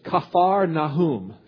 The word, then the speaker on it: Kafar Nahum: Literally, it's Kafar Nahum, if you want to pronounce it correctly. Kafar Nahum, that means the village of Nahum, Nahum, N-A-H-U-M, the village of Nahum.